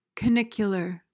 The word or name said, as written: canicular